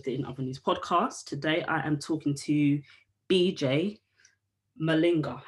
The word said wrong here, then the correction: Malanga: Have I said that right? – Yeah, BJ Malanga. – Say it again. – Malanga. Malanga. Malanga. Malanga. You know, like Nolinga, Nolinga, Nolinga.